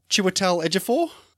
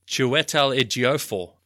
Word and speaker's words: Chiwetel Ejiofor: How about Chiwetel Ejiofor? – Sorry, I don't think that's how you pronounce it. – How is it? – Chiwetel Ejiofor.